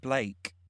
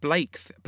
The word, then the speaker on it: Black: You don't pronounce it Blake. – No, not Blake as in Blake. Like, not Blake, it's Black.